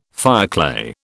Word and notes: fireclay: British English

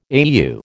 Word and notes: AEU: American English

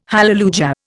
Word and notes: hallelujah: Indian

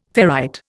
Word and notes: ferrite: Indian

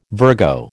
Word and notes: Virgo: American English

